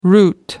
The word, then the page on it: route: American English